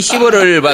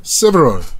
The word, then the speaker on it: several: Several.